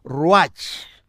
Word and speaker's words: ruach: Las palabras bíblicas para espíritu son ruach, R-U-A-C-H, en hebreo, ruach, y neuma, con la P delante, que no se pronuncia, neuma, en griego son términos onomatopéyicos ¿eh?